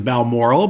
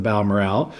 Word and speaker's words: Balmoral: It's actually the Balmoral, but locally the folks pronounce it as the Balmoral, a well-known landmark and watering hole here in Barry's Bay.